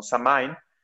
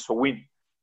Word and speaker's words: Samhain: Entonces, mientras que él, lo voy a pronunciar mal porque en el gaélico se pronuncia diferente, pero todos en, Occidente lo pronunciamos, lo pronunciamos como Samain, en el Samhain se celebra la oscuridad, básicamente, ¿no? De ahí viene el Halloween, ¿no? De hecho, la pronunciación de, de Samhain es Sowin, aproximadamente, ¿no?